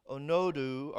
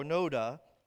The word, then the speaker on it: Onoda: It's Hiro Onodo, or Onoda, I guess, is how you pronounce that.